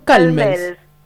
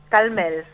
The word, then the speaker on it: Calmels: Cal, Calmels, está bien, ¿no? – Calmels. Calmels. – Calmels. – Ah, bien. un acento en la E.